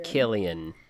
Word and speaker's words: Cillian: Killian.